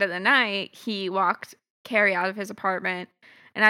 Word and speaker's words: Cari: And he said that Kari, her name is spelled C-A-R-I, but it's Carrie.